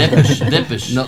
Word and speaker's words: Depeche Mode: Защото Depeche Mode съм го слушал още като бях студент. – Това ударение ми хареса. – Depeche.